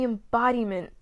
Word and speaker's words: embodiment: Almost everything you see her wearing is the embodied. Ugh. I didn't even pronounce the D on that. Wow, you really showed off your accent. They are the embodiment of early 2000s fashion.